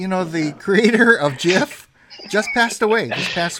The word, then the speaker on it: GIF: But he says GIF, so I will honor his memory and say GIF, despite my distaste for that pronunciation.